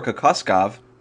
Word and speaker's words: Kokoskov: The Suns have hired Jazz assistant Igor Kokoskov to be their new head coach.